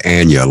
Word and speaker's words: Anya: I believe it's pronounced Anya, like aneurysm. Okay, Anya, Anya.